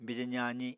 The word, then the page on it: bije zaazi: Apatani